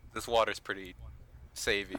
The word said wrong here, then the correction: savvy: It's pronounced savvy.